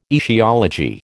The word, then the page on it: aetiology: American English